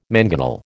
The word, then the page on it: mangonel: American English